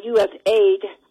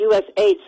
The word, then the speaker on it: USAID: And I just want to say that I did some research this weekend on the U.S. aid for an editorial I wrote, and I pronounce it like Mark Levin, U.S. aid. Some people say USAID, but the point of it is that this is a executive order that was written out by John F.